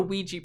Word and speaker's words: Ouija: It's called a Ouija board. That's how you pronounce it.